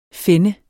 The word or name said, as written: fenne